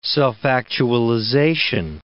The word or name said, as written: self-actualization